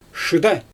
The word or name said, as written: ʃɯda